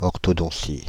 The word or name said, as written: orthodontie